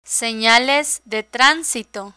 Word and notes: senales de transito: spanish

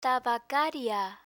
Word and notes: tabacaria: Spanish

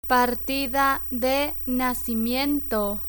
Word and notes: partida de nacimiento: Spanish

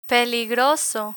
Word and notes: peligroso: Spanish

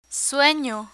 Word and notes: sueno: Spanish

